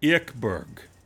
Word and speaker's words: Ekberg: Anita Ekberg is actually pronounced apparently Ekberg. – Mm. – Yeah, like a E. Ekberg.